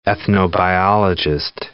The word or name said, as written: ethnobiologist